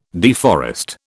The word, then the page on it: deforest: British English